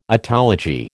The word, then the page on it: otology: American English